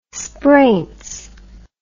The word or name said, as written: spraints